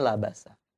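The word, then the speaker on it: labasa (لَبَسَ): Ya, jadi kita bacanya labasa apa labisa? Ya, kalau artinya bercampur, kita bacanya labasa. Ya, tapi kalau memakai, mengenakan baju gitu, kita bacanya labisa.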